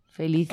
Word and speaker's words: felicífico: Acento en la segunda I. – Felicífico.